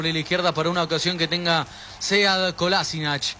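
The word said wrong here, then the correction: Kolašinac: O mejor dicho, Kolasinac, así como el señor lo pronuncia.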